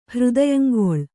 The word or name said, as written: ಹೃದಯಂಗೊಳ್